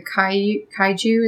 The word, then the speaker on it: kaiju: kaiju, is that how it's pronounced? Mm-hmm.